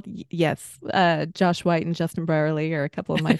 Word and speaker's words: Brierley: And then someone you've introduced me to is Justin, I'll say his last name wrong, Brierley. I said it right.